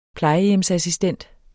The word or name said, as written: plejehjemsassistent